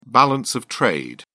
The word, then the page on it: balance of trade: British English